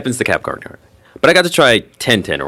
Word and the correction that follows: Temtem: or however you pronounce it. – Temtem? How is that? Temtem.